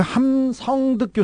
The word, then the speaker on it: 함성득: 그 김영준 교수하고 함승덕 교수가 이렇게 나오지 않습니까? 그 함성득 교수인데 고려대 대통령학 한 분인데 그그 그 대국 분들 청와대 주변에서는 발음이 잘 안 돼서 함승덕으로 불려요.